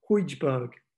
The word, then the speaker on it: Højbjerg: I've gone for Højbjerg. – Nice to see your pronunciation is improving. Højbjerg is in his centre midfield just for being a tank.